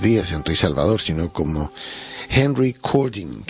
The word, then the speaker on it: Henry Cording: Henry Cording, si se fijan un poco como suena, recording, es decir, la palabra Henry, pero al estar, digamos, con Y, pues coge la pronunciación inglesa, ¿no? Y como era, digamos, una estrella del rock and roll, pues tenía un nombre anglosajón. Y Cording, que sería el apellido Cording, si lo juntas en recording, ¿no?